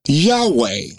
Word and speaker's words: Yahweh: With the vowel points, which you don't know anything about, we'll pronounce it Yahweh. – Mm-hmm. – Without the vowel points, it's Yahuwah. – Mm-hmm. – And some will even pronounce it Yahuwah.